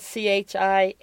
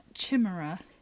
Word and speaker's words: chimera: Like the Chimera, which is spelled Chimera. – See, I was pronouncing that Chimera. – Yeah, it depends. I think it depends on what. Where came you from? – Well, and I don't know if I looked that one up because I was cocky and thought, well, I know how to pronounce this one. – Well, it said Chimera. – I think you're right. That sounds much cooler than Chimera.